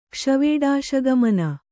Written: ಕ್ಷ್ವೇಡಾಶಗಮನ

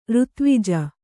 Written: ಋತ್ವಿಜ

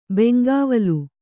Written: ಬೆಂಗಾವಲ್

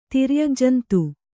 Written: ತಿರ್ಯಗ್ಜಂತು

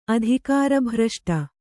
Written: ಅಧಿಕಾರಭ್ರಷ್ಟೆ

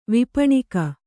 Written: ವಿಪಣಿಕ

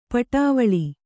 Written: ಪಡವಳಿ

